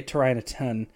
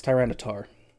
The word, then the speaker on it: Tyranitar: I always say Tyranitar. It's Tyranitar. My bad.